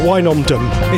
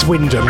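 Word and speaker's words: Wymondham: A lot of people call it Wynondum, because it actually looks how it's, people say it how it's spelled, Wynondum, it's not, it's Wyndham.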